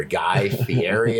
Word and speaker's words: Guy Fieri: Guy Fieri, is how you say his name.